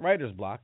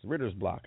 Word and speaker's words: Ritter's Block: Not Writer's Block, it's Ritter's Block, I think. It's spelled Ritter's Block.